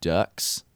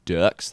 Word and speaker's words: Duhks: After that was Moses Don't Get Lost by the Ducks. And then after that was The Sweet by Hackensaw Boys. Sorry, I felt the need to say the Ducks that way because it's spelled D-U-H-K-S.